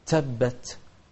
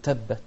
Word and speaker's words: تبت: تبت التاء هني الأولى والأخيرة في هذه الكلمة التاء من أحرف الهمس، لكن لازم ننطق فيها باعتدال، فما نقول تبت ما نطلع معاها سين، ما نقول تبت لا نقول تبت، ولا نحبس عليها زيادة ما نقول تبت لا ت ت.